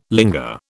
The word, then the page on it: linger: British English